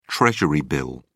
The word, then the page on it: treasury bill: British English